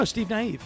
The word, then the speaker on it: Nieve: And Stevie, I'm going to say his name wrong, Neve? No, Steve Nieve.